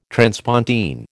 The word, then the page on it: transpontine: American English